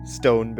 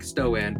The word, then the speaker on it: Stoan: Stone berry. – That's the one. – It's a stoneberry, really? – Stoan. Who knows? – It looks like Stoan, but it is pronounced just stone.